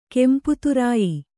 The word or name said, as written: ಕೆಂಪುತುರಾಯಿ